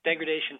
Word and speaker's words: Degradation: And Chris, I wanted to ask you, I was a big fan of the Jerry Cantrell solo record, how's it pronounced? Degradation? – Yeah, Degradation Trip.